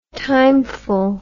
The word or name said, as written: timeful